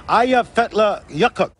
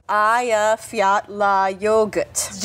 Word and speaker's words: Eyjafjallajökull: The glacier is called Eyjafjallajökull. – Eyjafjallajökull. Just think of, hey, you forgot your yogurt.